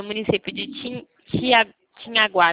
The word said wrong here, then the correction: Tianguá: né? – Tianguá, perdão, desculpa a pronúncia, tá?